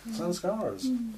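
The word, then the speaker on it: saṃskāra: What's known as sanskaras. Even the pronunciation I'm using, I hope you hear the resonance. Sangsara, sanskara. It has a similar feel to the word, which implies the circularity.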